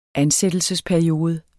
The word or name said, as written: ansættelsesperiode